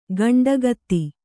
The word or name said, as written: ಕಂಠೋಕ್ತ